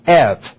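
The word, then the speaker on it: ev (εὐ): It is E-U, but that is actually pronounced in the Greek language ev. There is not that hard V sign, and that is the same prefix we would find at the beginning of evangelism, for example, evangelio, good message.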